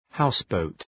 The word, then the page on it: houseboat: ‘haʋsbəʋt